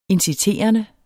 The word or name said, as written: inciterende